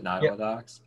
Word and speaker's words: Nottawa Docks: So what's next for, it's Nottawa Docs, right? That's how you pronounce it, Nottawa Docs? – Yep.